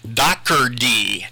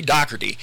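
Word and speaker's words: Daugherty: A lot of people look at his name and say his name's Dougherty. It's not, maybe that's what it looks like. It's not. It's Dougherty. Dougherty. Harry Dougherty.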